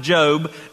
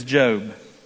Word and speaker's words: Job: If you have your Bibles, turn with me to the book of Job. Yes, it's pronounced Job, not Job.